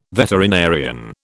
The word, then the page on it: veterinarian: British English